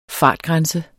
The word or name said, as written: fartgrænse